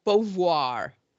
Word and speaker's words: Beauvoir: But it's like Beauvoir. – Oh, so it's not like Jackie O. Bouvier. That was Bouvier.